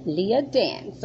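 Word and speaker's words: Leiah Dance: So you can find that, S-P-I-F-I, SPIFY. And you can find that from Leah Dance. – Leah Dance, okay. And Leah is spelled? – L-E-I-A-H. And Dance, D-A-N-C-E.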